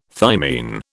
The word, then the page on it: thymine: British English